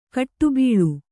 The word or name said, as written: ಕಡುಬಲಿ